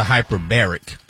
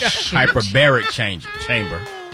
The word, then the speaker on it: hyperbaric: That's a hyperbaric. That's a whole different word, a whole different term, a whole different part of language. Hyperbaric chamber. It's not hyper. Yeah, your syllable pronunciation was wrong.